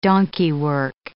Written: donkeywork

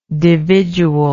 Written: dividually

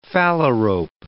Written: phalarope

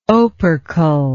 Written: opercle

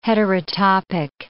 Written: heterotopic

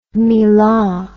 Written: milah

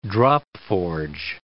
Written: drop-forge

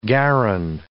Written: garron

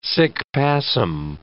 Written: sic passim

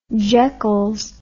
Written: jekylls

